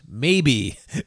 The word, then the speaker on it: Mabe: Funky names, too, like Teka, T-E-K-A, Altus, Ancor. But my favorite Latin American appliance brand name of all is M-A-B-E, pronounced maybe.